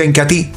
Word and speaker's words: Incatea: ¿Para qué me ayuda el Incatí? Se escribe Incatea, pero se pronuncia Incatí.